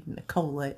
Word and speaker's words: Nikola: So I'm huge, huge, huge, huge on numerology, especially the numbers 3, 6, 9, the 3 sequence, which was very popular to Nikola is the correct pronunciation.